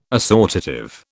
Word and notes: assortative: British English

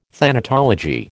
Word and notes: thanatology: American English

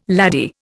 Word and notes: laddie: Indian

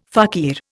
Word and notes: fakir: Indian

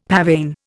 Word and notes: pavane: Indian

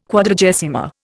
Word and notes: Quadragesima: Indian

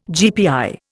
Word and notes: GPI: Indian

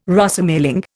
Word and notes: Rosemaling: Indian

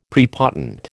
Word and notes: prepotent: American English

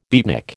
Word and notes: beatnik: American English

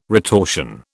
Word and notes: retortion: British English

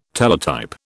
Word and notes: Teletype: British English

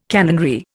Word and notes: canonry: Indian

